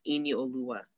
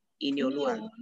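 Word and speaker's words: Inioluwa: But my full first name is Ini Oluwa. Ini Oluwa. – It's not that hard to say. – So it's like, right. It's only like Ini Oluwa. It's five syllables. Ini Oluwa.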